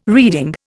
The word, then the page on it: reeding: Indian